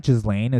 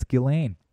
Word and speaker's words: Ghislaine: Ghislaine, apparently you don't pronounce the S. Did you know that? It's not Ghislaine, it's Ghislaine. Thank you, Amy Robuchar, or whatever the robot, I can't, fuck.